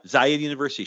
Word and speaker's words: Zayed University: Did I say that right? – Yeah, Zayed University, Sheikh Zayed University.